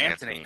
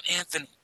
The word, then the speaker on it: Antony: Mark Antony. – Mark Antony. Yeah. You just killed Mark Antony. Not Anthony.